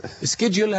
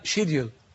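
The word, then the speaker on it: schedule: أو سكيدجل، هذه كلمة أمريكية. – نعم. – الإنجليزي ينطقونها شيديل. – نعم. – شيديل الإنجليزي يقولوا شيديل. نعم. والأمريكي يقول سكيدجل.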